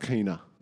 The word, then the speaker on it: Quena: I'm pretty sure it's pronounced Quena. – Well, when I asked Google, whatever. – They said Quena.